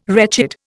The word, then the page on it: wretched: Indian